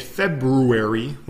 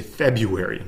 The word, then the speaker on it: February: Now, the R in this one is silent for some reason, or the first R is, right? So instead of saying it February, we say February. We just like take out that R.